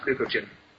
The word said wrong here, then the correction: glucagon: I really don't trust it. – Glucagon.